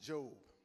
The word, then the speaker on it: Job: It's spelled like Job, but it's pronounced Job.